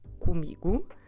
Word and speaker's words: comigo: So we've done the first one, really important, comigo.